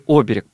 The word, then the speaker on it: оберег: Употребляют его именно с таким ударением, с каким его употребил Александр, оберег, но все-таки оберег, правильно? – Да.